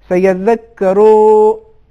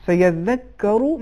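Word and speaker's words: سيذكر: مثال کے طور پر کہیں کہیں پہ آپ نے حرکتوں کو زیادہ کھینچا، زبر زیر پیش، سیدک کرو، یہ زیادہ ہو گیا، اتنا نہیں کھینچنا چاہیے، اور اس کا لہجہ بھی لہجہ عربی کے مطابق ہونا چاہیے. تو صحیح تلفظ یہ ہے سیدک کرو من یخشا.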